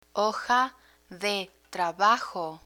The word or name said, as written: hoja de trabajo